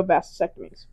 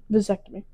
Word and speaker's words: vasectomy: This is aside from the complications women experience, unlike men who undergo vasectomies. Did I say that right? – Vasectomy.